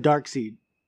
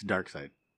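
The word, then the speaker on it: Darkseid: Dark side? – Is it seed or dark? – You said dark seed. – Yeah, dark seed. Attaboy. Yeah. It's dark side.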